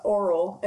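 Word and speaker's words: Orual: Oral is how Grandma has proposed to pronounce it.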